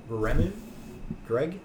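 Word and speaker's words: Wiremu Greig: William, it's hard to pronounce. Yep. Wiremu? Greg? – Wiremu Greg, yeah.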